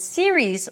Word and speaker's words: series: The word series, on the other hand, ends with a z sound. Series. So you close it with a z sound, although it's spelled with an s, okay? Series. Serious, series. Three syllables, serious, two syllables. Series, all right?